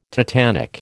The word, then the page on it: tetanic: American English